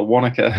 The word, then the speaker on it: Wanaka: He's coming to us all the way across from the Tasman, from New Zealand over there, from Wanaka. Is that pronounced correctly? Is that the correct way to pronounce that? – For you guys, it is. For us, we call it Wanaka.